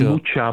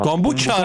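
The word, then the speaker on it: kombucha: Ale, tak mám ti řekni, jestli vyslovuješ kombuchu, jestli vyslovuješ kombuchu s ch, nebo s č. – Kombuča, pro boha. – Kombuča, jo.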